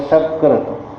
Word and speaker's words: सकृत: बोलना चाहिए आपको सकृत, आपने उच्चारण किया शकृत।